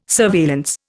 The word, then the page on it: surveillance: Indian